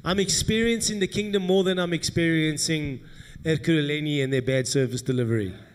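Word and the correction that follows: Ekurhuleni: I can never get that word right. How do you say Ekulu? Ekuruleni. Ekuruleni.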